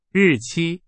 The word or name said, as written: 日期